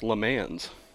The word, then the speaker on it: Le Mans: February 28, 1979 in the famous racing capital of France, Le Mans. – I believe it's pronounced Le Mans here in America.